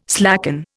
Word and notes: slacken: Indian

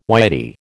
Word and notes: Whitey: American English

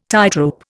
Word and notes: tightrope: Indian

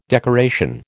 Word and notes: decoration: American English

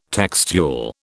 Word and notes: textual: British English

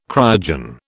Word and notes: cryogen: British English

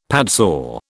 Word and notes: padsaw: British English

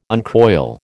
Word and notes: uncoil: American English

